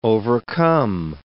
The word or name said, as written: overcome